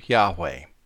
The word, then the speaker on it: Yahweh: Let's consider the predominant name for God, the Father, as revealed in the Old and New Testaments, pronounced either Jehovah or Yahweh.